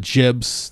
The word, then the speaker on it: gib: You know, like there were a lot of jibs. That's how John Romero says to pronounce it. I'm a gib guy. I'm a hard G guy, I got to admit. – Yeah, it's tough. – Yeah. Like he's like, so I was interviewing John Romero and I said Gibbs. He's like, ah, it's Jibs.